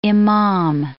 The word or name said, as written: imam